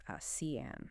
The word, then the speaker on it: ASEAN: And worth noting, in the region, you'll often hear ASEAN pronounced as CN.